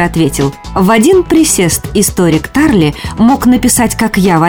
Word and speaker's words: Тарле: Если запомнить этот стишок, то уже никогда не ошибешься в ударении, произнося фамилию замечательного русского ученого академика Тарли. А то многие путают.